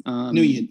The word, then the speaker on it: Nguyen: Yeah, so actually we have a study by Nguyen, I'm mispronouncing the name horribly, Nguyen probably. – Nguyen, yep, that's it.